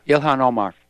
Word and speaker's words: Ilhan Omar: That lady, lady congressman, how do you pronounce her name? Ilhan Omar? – Yeah, Ilhan Omar.